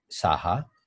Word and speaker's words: Saha: My full name is Prince Sanjay Saha, PRINCE SANJAY, and last name SAHA.